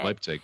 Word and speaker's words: Leipzig: Leipzig? I don't know how to say it. – Leipzig.